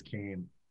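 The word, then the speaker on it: Keem: But the only problem is that beach in Achill is actually pronounced locally as Cane.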